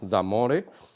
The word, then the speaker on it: Damore: El caso más famoso es el de James Damore, no sé si os suena, se escribe Damore, porque de hecho viene del italiano, Damore.